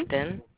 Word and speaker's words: Kestin: K-I-R-A-Y-A. – Mm-hmm. – Keston. K-E-S-T-I-N.